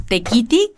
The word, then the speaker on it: Tekiti: En Instagram está como Tequiti, que se escribe T-E-K-I-T-I MX.